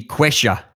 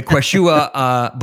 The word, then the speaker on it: Quechua: The Quechua. – Well done. – The Quechua Bolivian indigenous people.